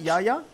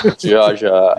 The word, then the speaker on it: Yaya: Yaya? – I'm not the right person to answer that. – It's pronounced Jaja.